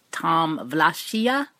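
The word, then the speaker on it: Tom Wlaschiha: I looked it up, and I think that is how you pronounce it. The actor who plays Jaqen H'ghar. It's Tom Vlachia. So now we can all say it properly.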